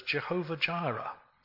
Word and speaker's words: Jehovah Jireh: which we pronounce as Jehovah Jireh.